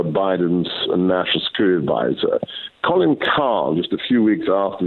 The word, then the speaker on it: Kahl: But there's also uh, a good friend of his called Colin Kahl, pronounced K A H L,